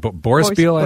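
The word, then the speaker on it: Boryspil: There's a sister city, Brospril, in Ukraine that they are supporting. Did I say it wrong? If I said it wrong, correct me. – You did, but Borspiel, I think, is closer.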